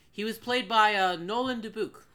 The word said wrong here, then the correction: Dubuc: Nolan... what? – Dubuc, I think. – Dubuc? – I don't know how to pronounce his last name. – Dubuc. – D-U-B-U-C. Nolan Dubuc.